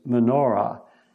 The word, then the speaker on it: menorah: The English pronunciation is menorah. In Hebrew, it's like menorah.